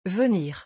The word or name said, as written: venir